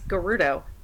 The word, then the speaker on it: Gerudo: And I just kind of looked at you and I went, Ocarina of Time Ganon, I'm the first Gerudo person born every 100 years. And you went, you goddamn asshole, you're playing to my weaknesses. – It's Gerudo.